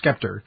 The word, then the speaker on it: sceptre: Am I saying that right? The Sovereign Scepter. – I was thinking scepter, but... – Scepter? It's spelled S-C-E-P-T-R-E. So, I'm sorry. – I think it's a silent C.